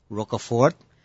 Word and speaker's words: Roquefort: The most famous is Roquefort.